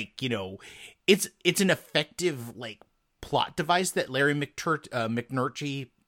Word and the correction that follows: McMurtry: I can never say his name. – Larry McMurtry. – Yes, exactly. Larry McMurtry uses, because he does this in a lot of his stories.